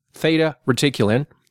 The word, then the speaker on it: Theta Reticuli: This radar brought down several Theta Reticulin. Reticulin. Can you pronounce that? – Yeah, Theta Reticulin. – It's Reticulin. – Theta Reticuli. It's a 38 light year from here, place where these aliens live.